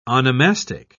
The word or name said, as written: onomastic